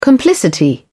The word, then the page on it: complicity: British English